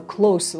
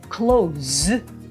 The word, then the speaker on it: close: close 近いの意味で使う close は、えっと、発音 close になります、えっと、閉まっている場合は同じスペルなんですけど同じ綴りなんですけども close と読むので発音が違うの